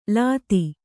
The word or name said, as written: ಲೂತಿ